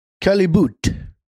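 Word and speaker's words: Caillebotte: He is a French painter called Gustave Caliboot, which is exactly how you say it. Exactly how it's pronounced.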